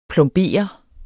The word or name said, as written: plombere